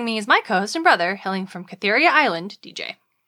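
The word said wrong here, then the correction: Kythera: It's pronounced Kithera.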